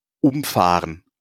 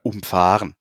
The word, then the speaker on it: umfahren: Also, was ich damit meine ist, umfahren oder umfahren. Also du kannst ja quasi... Ja, allein von der Betonung wird es ein ganz anderes Wort.